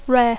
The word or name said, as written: rare